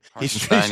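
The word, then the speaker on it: Hartenstein: There was one where the commentators blatantly called, it was on a Harkenstein. Is that how you pronounce his name? – Harkenstein, yeah.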